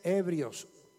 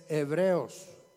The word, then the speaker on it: Hebreos: Pronúncialo bien, porque hay quienes dicen ebrios. Es Hebreos.